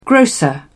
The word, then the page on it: grocer: British English